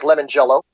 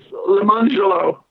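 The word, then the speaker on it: Lemongello: So you've Mark Lemangelo, I believe is the pronunciation. It looks like lemon jello. – Lemon jello. – Don't pronounce the lemon jello because it's Lemangelo.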